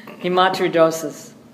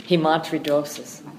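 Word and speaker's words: hematohidrosis: Hematidrosis. That's how I usually pronounce that. It finally came back to me. – How do you pronounce? – Hematidrosis.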